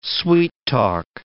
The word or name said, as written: sweet-talk